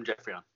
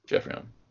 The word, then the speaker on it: Geoffrion: is it Boom Boom Geoffrion? Or how do you pronounce it? – Yeah, it's Boom Boom Geoffrion. Yeah. – Geoffrion. – In French, you could say Geoffrion, but like... – Geoffrion. – It's pretty much the same thing, right? – Yeah.